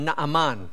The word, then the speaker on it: Naaman: Actually in our language we would pronounce his name Naaman.